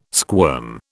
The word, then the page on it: squirm: British English